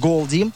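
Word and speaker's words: Goldie: Это название читается как Голди. Голдие.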